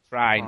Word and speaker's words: Trine: And I've also played Trine, which I know how to pronounce now since I've played the game. – What is it called? Trine? – Trine.